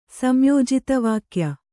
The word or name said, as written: ಸಂಯೋಜಿತವಾಕ್ಯ